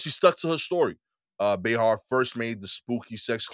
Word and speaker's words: Behar: Joy Behar continues to insist she had a menage a trois with Ghost.